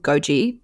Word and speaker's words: Gouqi: And then later on in the week, I went to a restaurant called Goji, but that is not, you wouldn't know, it's spelt G-O-U-Q-I.